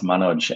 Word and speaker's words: Manoj: So it's actually pronounced Manoj, and it was given to me by my auntie, who was in love with an Indian movie star.